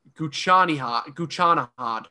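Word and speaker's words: Ghoochannejhad: His name is Reza Gucci-an-Jahad.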